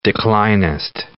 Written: declinist

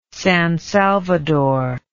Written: San Salvador

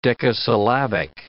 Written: decasyllabic

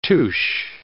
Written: tusche

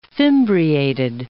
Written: fimbriated

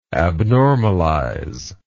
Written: abnormalize

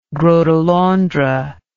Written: gros de Londres

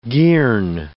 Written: girn

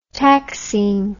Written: taxine

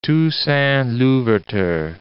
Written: Toussaint-Louverture

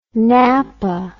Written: nappa

